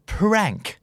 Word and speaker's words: prank: นะเริ่มต้นที่คู่นี้ฮะถ้าเกิดออกเสียงตัว R มันจะหมายถึงการแกล้งหรือว่าการเล่นตลกแต่ถ้าสมมุติเกิดออกเสียงเป็นตัว L จะเป็นท่าออกกำลังกายที่ไม่ต้องเคลื่อนไหวแต่เหนื่อยแล้วก็เมื่อยหน้าท้องมากๆคำคู่นี้คือ prank กับ plank p r a n k prank p l a n k plank แบบ planking